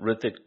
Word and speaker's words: Hrithik: He seems to come from a big Hrithik. Yeah, the age is silent. Hrithik Roshan.